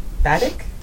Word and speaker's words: batik: batik? I don't know how to say it. Batik, B-A-T-I-K.